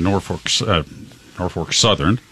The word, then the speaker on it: Norfolk Southern: Union Pacific in Norfolk Southern, I got to make sure I say that correctly. – Yep, you did.